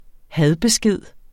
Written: hadbesked

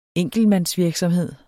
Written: enkeltmandsvirksomhed

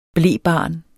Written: blebarn

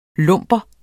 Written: l'hombre

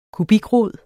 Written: kubikrod

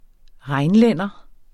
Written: rheinlænder

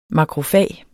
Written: makrofag

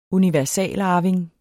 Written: universalarving

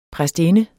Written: præstinde

